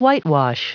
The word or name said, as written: whitewash